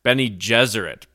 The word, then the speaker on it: Bene Gesserit: Bene Gesserit. Bene Gesserit. Okay. – Bene Gesserit, yeah.